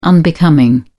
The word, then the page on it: unbecoming: British English